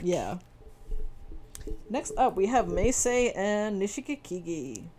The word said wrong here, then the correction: Nishikigi: I think I added an extra syllable in there. – You did, but it's all good. I was going to let that ride. – Nishikigi.